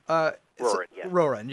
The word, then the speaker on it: Roran: My favorite character was actually Roran, if I'm pronouncing that correctly. – Roran, yes.